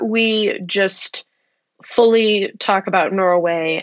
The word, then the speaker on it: Norwhey: And that is he is a co-owner of the company Norway, and that is spelled N-O-R-W-H-E-Y, because this is a product that is brewed from yogurt whey.